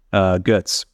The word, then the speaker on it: Goetz: Adam Goetz. I'm saying that the right German way.